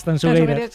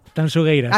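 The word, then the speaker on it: Tanxugueiras: A ver, repítemelo, que me tengo que pronunciar bien. – Tanzugueiras. – Sí, esto me lo corrige Fermín Agustín mucho. Por favor, digo, mira que yo siempre pronuncio... A ver, otra vez, dímelo. – Tanzugueiras. – Acaba de deshacerse la manifestación a las puertas de la Gran Vía, de lo bien que lo has dicho.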